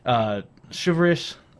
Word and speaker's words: chivalrous: But in the Celtic belief that it's a very chivalrous, silver. Oh, sorry, how do you say it? Chivalrous, right? Is mm-hmm. that correct? – Yeah, sure.